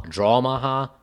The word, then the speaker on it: Drawmaha: This small intricacy leads to why this game goes by the name of Drawmaha instead of Dramaha and goes by an alternate name, Sviten Special.